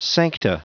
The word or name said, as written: sancta